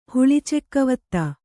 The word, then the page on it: ಹುಳಿಚೆಕ್ಕವತ್ತ: Kannada